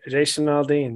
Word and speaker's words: Jason Aldean: Is that, am I saying his name wrong? – No, no. You said it. Jason Aldean.